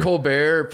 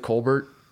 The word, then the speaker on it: Colbert: Stephen Colbert pronounces it Colbert, and he pronounces it as Colbert. That's a true. I remember he had his brother on a show one time, and his brother is Colbert, and he's Colbert.